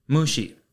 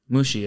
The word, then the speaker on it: mushi: Today's Lessons is going to be the Japanese word for insect, and that is mushi. Mushi is spelt M-U-S-H-I